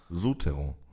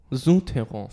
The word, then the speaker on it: Souterrain: Souterrain. Wieso kannst du das so ad hoc aussprechen? – Ich meine, Südtirol. Su, Su. – Südterrasse. Das ist französisch, ne? – Ja. Für Südterrain? was? Souterrain. – Souterrain.